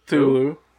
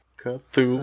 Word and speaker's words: Cthulhu: C-H-U. – Nope. – What? – Nope. – C-H? – Nope. – Okay. – C-T-H. – Yeah. – Ah, fuck that shit. Those silent letters. – Fuck, dude's been lifting. He's got some fucking arms on him. No, Cthulhu. Cthulhu.